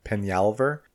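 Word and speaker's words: Peñalver: And then, so Diana Penyalver, the emphasis on the N, trying to say it correctly.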